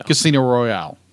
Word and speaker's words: Casino Royale: Casino Royale.